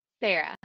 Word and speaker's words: Sarah: So I say Sarah differently than how people say Sarah. So my wife says you say it like Sarah. Mm-hmm. I don't think I say it like that, but she says I do. But, you know, is it Sarah? How do you say Sarah? – Sarah. – See? You say it like I do, Sarah.